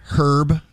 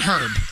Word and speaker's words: herb: You call it herb? – I call it herb. I know it's herb, but I call it herb because I see the H and I pronounce it.